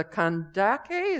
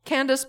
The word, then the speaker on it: Kandake: Now there was an Ethiopian eunuch, a court official of the Kandake, is that how you pronounce it? I would say Candace, but then I know that you're right and I'm wrong.